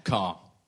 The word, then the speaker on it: Kerr: So that was you and Tom Tuck and Humphrey Kerr. – Car. Spelt like. – I thought you were saying vehicle. – Yeah. Okay. – Car. Well, no, car. It's not spelt like the vehicle.